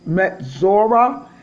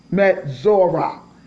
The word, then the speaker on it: Metsora: and it's called Metzora. It's spelled M-E-T-S-O-R-A, Metzora.